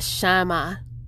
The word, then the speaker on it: Shammah: Jehovah Shammah. And that's spelled S-H-A-M-M-A-H.